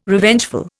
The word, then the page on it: Revengeful: Indian